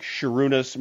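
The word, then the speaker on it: Šarūnas: I also think that that little serif on top of the S means you pronounce it with a sh sound, sure. – like Sharunas, Marcellonas, that is a Lithuanian pronunciation.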